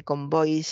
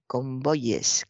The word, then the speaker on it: convoyes: No se dice convoys, se dice en plural convoyes, convoyes. Convoyes, eh, pues por ejemplo, como ley, que se dice leyes, o buey, que se dice bueyes, o como hay, que se dice ayes.